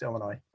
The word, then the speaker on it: Illinois: The S is silent. It's Illinois.